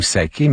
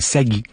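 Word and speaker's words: Sakay: Après Jack et Me Too, on a entendu, il faut bien prononcer comme il faut, ça s'écrit, on voit nous saquer, mais c'est Sagui.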